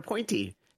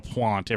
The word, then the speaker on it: Pointe: except it was P-O-I-N-T-E is how it's pronounced. – Perimeter Pointy.